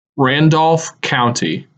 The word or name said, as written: Randolph County